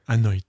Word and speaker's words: Anoint: Alors, je ne sais pas comment ça se prononce, mais c'est Anoint. A-N-O-I-N-T. Je ne sais pas comment. – D'accord. Oui. Oh, oui, tu t'en sors bien.